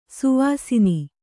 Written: ಶವಾಸನ

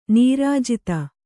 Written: ನಿರ್ಜಾತ

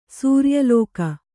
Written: ಸೂರ್ಯಲೋಕ

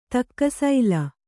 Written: ತಕ್ಕಸೈಲ

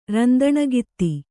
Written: ರಂದಣಗಿತ್ತಿ

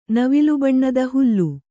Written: ನವಿಲುಬಣ್ಣದ ಹುಲ್ಲು

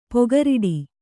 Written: ಪೊಗರಿಡಿ

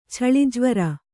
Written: ಛಳಿಜ್ವರ

